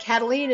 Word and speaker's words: Kataline: Karen is spelled the normal way, no goofy Y's or Q's, K-A-R-E-N. and Kataline is spelled K-A-T-A-L-I-N-E.